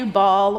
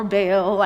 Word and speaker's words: Baal: But you can say Baal or Baal. I think in the Greek it's a short A.